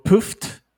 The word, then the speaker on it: Phffft: I watched a film by the name of Pooft. It's spelled P-H-F-F-F-T exclamation mark. It's like the sound.